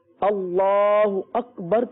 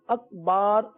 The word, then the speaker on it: اللہ اکبر: اللہ اکبر کہنا ہے, اکبر یا اکبار ہرگز نہیں کہنا.